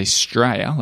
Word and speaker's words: Straya: But it's interesting because Australia they say Straya, like you said, 2 syllables.